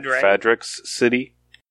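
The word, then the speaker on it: Fadrex: Hoid is in how do you say Fadric? – Fadric's city. – Is it Fadric's? We pronounce the X? – Yeah, in the audiobooks anyway.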